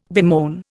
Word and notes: bemoan: Indian

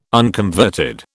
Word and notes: unconverted: British English

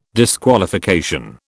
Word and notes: disqualification: British English

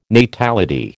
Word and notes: natality: American English

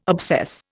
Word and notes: obsess: Indian

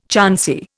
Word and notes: chancy: Indian

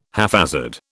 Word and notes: haphazard: British English

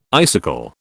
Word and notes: icicle: British English

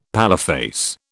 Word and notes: paleface: British English

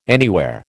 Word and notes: anywhere: American English

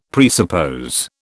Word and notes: presuppose: British English

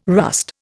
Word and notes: rust: Indian

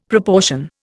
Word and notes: proportion: Indian

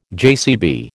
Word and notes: JCB: American English

